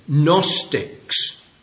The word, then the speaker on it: Gnostics: We call them Gnostics. Now, I'll spell that for the sake of young people. It starts with a silent G. G-N-O-S-T-I-C-S.